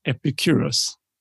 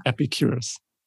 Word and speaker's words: Epicurus: So, so, so how you pronounce that is Epicurus. – Yeah. – Epicurus. Not curious. Okay.